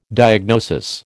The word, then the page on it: diagnosis: American English